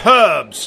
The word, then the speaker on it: herbs: It is herbs, damn it. There's an H, and that needs to be pronounced.